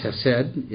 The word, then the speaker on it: chesed: The Hebrew word is Chen, God's grace or favor, and especially his chesed. I worked on that pronunciation. Chesed, or chesed, if you want to angleize it, which is God's loving kindness.